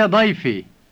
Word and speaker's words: ضيفي: You know that the E sound at the end of the word ضيف means my, and ضيفي is my guest. Now listen to, you are my guest. – انت ضيفي.